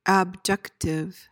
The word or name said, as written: abjective